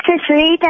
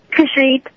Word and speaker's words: Kushreet: My name is Kushreet, and I think the answer is Arachne. – What's your name again? – Kushreet. – How do you spell it? – K-U-S-H-R-E-E-T.